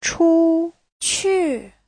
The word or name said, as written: chu1qu4